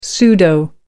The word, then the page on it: pseudo: American English